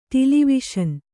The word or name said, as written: ಟಿಲಿವಿಷನ್